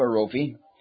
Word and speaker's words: Jehovah Rophe: And then you get Jehovah Rophe, or we pronounce it Rophi.